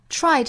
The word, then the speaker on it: try: Try 这个词要注意里面有一个 ch 和 r 的音素的组合。 Try. – 这个 r 的发音一定不要丢掉了哈。